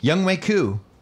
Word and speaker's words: Younghoe Koo: All right, in studio, Younghoe Koo. Did I say it right? – Yes, sir, you did.